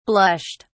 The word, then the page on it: blushed: British English